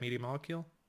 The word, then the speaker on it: Media Molecule: So for those who don't know Little Big Planet, it's from Media Molecule, right? I got that right, Media Molecule? – Yeah, you said it right.